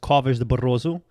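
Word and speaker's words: Covas do Barroso: In Portuguese, it's pronounced Covas de Barroso.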